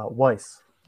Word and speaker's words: Weisse: Uh, Weisse.